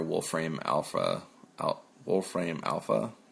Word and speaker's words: Wolfram Alpha: Yeah, it seems like they're utilizing a lot of their Wolfram Alpha. Is that, am I saying it right? – Yeah.